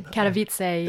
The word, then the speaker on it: Katowice: So it's is that the right pronunciation? – Katowice, yeah.